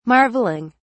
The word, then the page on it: marvelling: British English